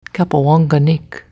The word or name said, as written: Cappawonganik